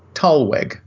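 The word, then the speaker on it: Thalweg: turns out there is an English word, it's based on German, which is pronounced Tollweg. Okay, sometimes spelled with an H because it comes from the German word T-H-A-L, Tal, pronounced that way in German, which means a valley.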